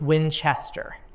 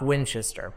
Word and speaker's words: Winchester: So when we were in Winchester, I keep saying that wrong. It is Winchester. They were very specific about that. It is Winchester, not Winchester, but Winchester, which seems backwards to me, because you would think Brits would say Winchester, but it's Winchester.